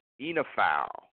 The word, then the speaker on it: oenophile: Today's word of the day is enophile. Enophile. Enophile is a noun, and it's spelled O-E-N-O-P-H-I-L-E. Enophile, enophile, okay?